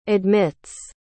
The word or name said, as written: admits